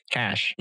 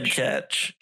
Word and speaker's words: cache: I've always pronounced it as cash. – It's because that's how it's pronounced.